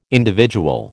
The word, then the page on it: individual: American English